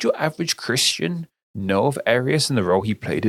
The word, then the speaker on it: Arius: And something I find interesting about this is Arius. Am I pronouncing that right? Arius? – Arius, yes. – Arius, yes.